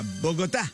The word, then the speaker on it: Bogotà: In seguito ad una rivolta della popolazione a Bogotà. – Con l'accento sulla A. – Certo, l'ho ben detto, l'accento su Bogotà.